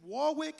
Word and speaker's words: Warwick: And then last week we had the honor and privilege of being in Warwick,